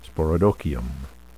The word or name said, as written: sporodochium